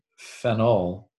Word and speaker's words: phenyl: And the name phenol, which is also important to note, because sometimes in my pronunciation, I want to hit phenol, which is the aromatic alcohol, and a phenyl, P-H-E-N-Y-L, which is a substituent, an aromatic ring system as a substituent is a phenyl group.